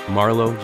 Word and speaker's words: Marlow: You can find myself at Marlo JR.